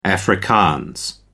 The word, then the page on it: afrikaans: American English